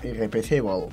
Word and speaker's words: Irreplaceable: Y bueno, pues para empezar así, vamos a poner un temazo de Beyoncé que se llama Irreplaceable, si lo he dicho bien.